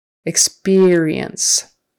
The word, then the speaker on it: experience: I'm very sorry to hear about your experience. Sorry to hear about your experience. Second syllable stress. Experience.